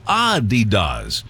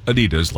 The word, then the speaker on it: Adidas: It's supposed to be Adidas with a slight emphasis on the first syllable, not Adidas like we stress the second.